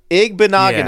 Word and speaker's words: Igbinoghene: Igbinoghene? – It is exactly how it's spelled. Igbinoghene.